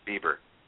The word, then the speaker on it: Bieber: Bieber.